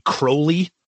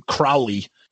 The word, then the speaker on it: Crowley: I guess apparently Ozzy mispronounces his name. It's supposed to be Crowley. I don't know if that's a British thing or something, but Ozzy calls him Crowley.